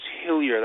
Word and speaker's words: Hillyer: My last name is Hillier. That's spelled H-I-L-L-Y-E-R.